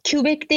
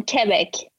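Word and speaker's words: Québec: Quebec değil yalnız canım Quebec.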